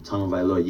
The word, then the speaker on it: Tagovailoa: So the last name, Tagovailoa, you can say Tagovailoa, just to make it easier. Say tongue first, and then you say O-H-O-V-A-E-Loa, L-O-A.